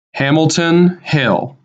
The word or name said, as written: Hamilton Hill